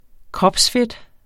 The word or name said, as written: kropsfedt